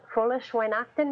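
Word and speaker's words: Fröhliche Weihnachten: Frohlich Weihnachten? Maybe I'm not saying it right. – You know what? I think you are.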